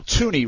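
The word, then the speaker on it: Thuney: This morning, it's Joe Tooney, right? I think the H is silent there on the Patriots guard.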